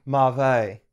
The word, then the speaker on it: mauve: And then people be like, hey, how do you pronounce M-A-U-V-E? It's a color. – Mauve. – It's mauve. – Dang it. – No, and everyone's listening. It's mauve. It's mauve.